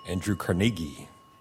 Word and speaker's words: Andrew Carnegie: Andrew Carnegie. – Beautifully pronounced and correct.